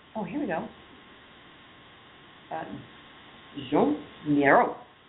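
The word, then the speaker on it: Joan Miró: You have to say it like that because it's French and there's a guy's picture next to it. So if you just say Joan, you're really doing a misservice.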